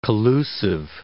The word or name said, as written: collusive